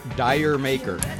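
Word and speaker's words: D'yer Mak'er: Now, it's spelled D apostrophe Y-E-R. – Okay. – Because the apostrophe for ID, it's and maker, M-A-K apostrophe E-R. – Oh, okay. – Because what it is, it's English slang.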